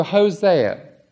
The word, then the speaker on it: Hosea: And turn, will you, to Hosea.